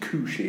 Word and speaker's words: Khushee: I think that's pronounced K-H-U-S-H-E-E. So I think it's Kushi.